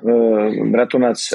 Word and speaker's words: Bratunac: Habe ich das richtig ausgesprochen? – Ja, Bratunac, aber das klingt gut.